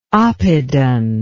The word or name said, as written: oppidan